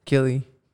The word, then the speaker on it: Killy: Gilly. Is it Gilly? – Killy. – K-I-L-L-Y.